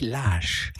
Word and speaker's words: Lage: Julian Lage schreibt er sich, nicht verwandt mit Klaus Lage. Also, er kommt aus Kalifornien und er spricht sich Lage.